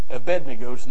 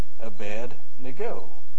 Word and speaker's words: Abednego: It wasn't until I watched Jeopardy that I pronounced it right, because I heard Alex Trebek pronounce it Abednego. Most of us say, what? Abednego, right? But if you look, it's actually Abednego, the servant of Nego, which is one of their gods.